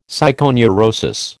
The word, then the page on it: psychoneurosis: American English